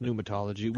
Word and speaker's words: pneumatology: For you, uh, I have the word pneumatology, uh, spelled pneumatology, but it's pronounced pneumatology, which is the study of the Holy Spirit.